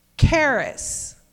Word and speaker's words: charis: The root word is charis. You can't get it? charis. That's the middle part. C H A R. I S, Charis There, yay, see?